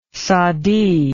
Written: saadi

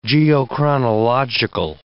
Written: geochronological

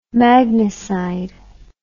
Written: magnicide

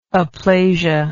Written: aplasia

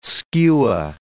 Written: skua

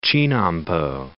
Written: Chinnampo

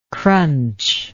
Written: crunchable

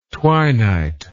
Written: twinight

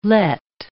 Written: Lett

